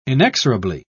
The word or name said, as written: inexorably